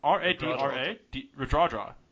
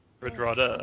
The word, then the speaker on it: Radradra: How do you pronounce his surname? Radradra. – Radradra, I think. – Yeah, that's it. Thank you. Radradra.